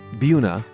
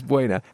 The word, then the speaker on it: Buena Vista: Buena. – That was a good pronunciation. – I'm impressed. – Nobody gets that. – Versus Buena.